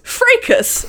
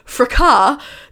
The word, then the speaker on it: fracas: The fracas, pronounced fracas, according to Merriam-Webster, although the British pronounce it fracas, began quietly with a tweet from a Potter fan, blah, blah, blah.